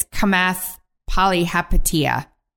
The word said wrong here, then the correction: Chamath Palihapitiya: Let me see. I'm going to do this with you. – Chamath Palihapitiya.